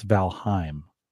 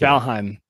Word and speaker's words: Valheim: I think we're both pronouncing it wrong. I think this is how it's pronounced. Did you hear that? – No. – Oh, so shoot. That should have switched over. One second here. Maybe I don't have the loop back. I think it's Valheim. – It might be.